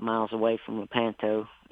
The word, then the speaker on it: Lepanto: And you were born in Lepanto, Arkansas. Correct me if I pronounce that wrong. But Lepanto, that's close enough.